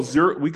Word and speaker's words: Zurcher: Am I pronouncing that right? – Yeah. Well, Zurcher. Zurcher is actually a name of a citizen of Zurich, actually.